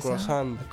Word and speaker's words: croissant: ¿Cómo se dice? Croissant. – Croissant. Lo dije yo, ¿qué era? Es que estuvo muy bien mi pronunciación y no captaste.